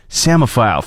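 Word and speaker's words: psammophile: To let you know how it is spelled, P-S-A-M-M-O-P-H-I-L-E. I heard the word before I saw it spelled, so I immediately thought samophile.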